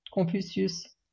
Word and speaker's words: Confucius: Confucius. Confucius, you know, the Chinese philosopher.